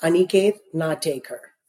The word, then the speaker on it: Aniket Natekar: I know I mispronounced that. Would you care to correct me? – Sure. It's pronounced Aniket Natekar.